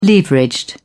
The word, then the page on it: leveraged: British English